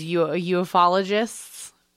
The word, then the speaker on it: ufologists: Some pseudoscience adherents, such as ufologists. I always think I'm saying it wrong, but that's how you say it.